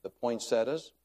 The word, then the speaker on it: poinsettia: The Rons helped us by bringing the poinsettias. If pronounced as spelled, it's poinsettia, I realize, although I did have a pastor's wife one time say she was requesting donations for poinsettias.